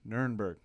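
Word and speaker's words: Nirenberg: Nutterbugger, whatever the hell his name is. – You need to get his name right. It's Nuremberg.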